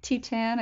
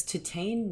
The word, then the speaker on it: Titane: I like Titane. I think that's the correct way of saying it.